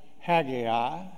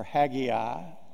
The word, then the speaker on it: Haggai: You can pronounce it Haggai or Haggai, or you can pronounce it Haggai. I think either one of them are appropriate.